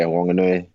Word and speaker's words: Wanganui: Yeah, Wanganui.